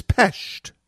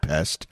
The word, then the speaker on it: Budapest: It is Pest, not Pest, but Pest. And I will always say it that way because that's the way the locals say it. And that's the way it should be said.